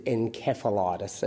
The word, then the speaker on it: encephalitis: We tend to go with the British pronunciation, which is encephalitis, as opposed to the American pronunciation.